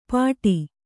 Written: ಪಾಡಿ

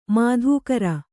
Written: ಮಾಧುಕರಿ

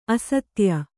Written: ಅಸ್ಥಾಯಿ

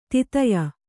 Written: ದೈತೇಯ

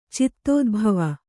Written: ಚಿತ್ತೋದ್ಭವ

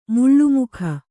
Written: ಮಾಳ್ಮುಕಿ

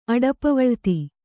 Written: ಅಡಪವಳಿತಿ

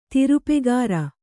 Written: ತಿರುಪೆಗಾರ

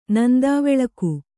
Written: ನಂದಾವೆಳಕು

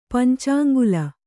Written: ಪಂಚಾಂಗುಲ

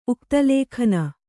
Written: ಉಕ್ತಲೇಖನ

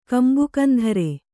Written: ಕಂಬುಕಂಧರೆ

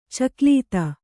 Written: ಚಕ್ಲೀತ

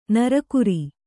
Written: ನರಗುರಿ

